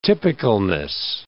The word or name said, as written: typicalness